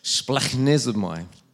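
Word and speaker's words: splachnizomai (σπλαγχνίζομαι): Now, I thought, how do you pronounce that? So I had to go onto a website that had a little thing that I could push and it says it, splachnizomai.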